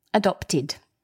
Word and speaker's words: adopted: And because the word adopt ends in a T, which is a dental consonant, we pronounce the ED, id, adopted.